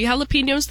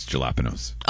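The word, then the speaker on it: jalapeños: I picked like two or three jalapenos the other day for the very first harvest, but it's not enough. It's not quite enough to pickle yet. – I think you said it wrong. It's jalapenos.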